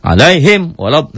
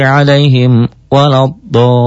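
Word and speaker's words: alaihim waladdallin (عليهم ولا الضالين): Oh jangan, jangan sekali-kali dibacanya alaihim walad, enggak. Ghairil maghdubi alaihim waladdallin. Itu hanya untuk membedakan, saking tidak bolehnya dibaca idgom. Harus dibaca jelas, dibaca idhar.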